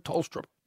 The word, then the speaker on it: Tolstrup: Apparently the H comes out. We were told last night, the Amori, who does all the research at Talking Footy, said Colton Tolstrup.